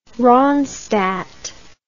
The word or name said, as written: ronstadt